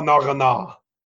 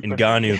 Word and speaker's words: Ngannou: Francois Nagana. – I'll you, I'll let you Ngannou for you everybody else that pronounces it correctly.